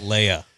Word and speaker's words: Leia: Leah. – Idiot. – Princess Leah. – Can't believe you didn't know that. – Jeez. Yeah. Oh, well. – Leia. – Sorry. – That's how you say it.